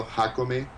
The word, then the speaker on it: Jacome: So I'm on, you know, Dribble under Mario Jacome. My last name is spelled J-A-C-O-M-E.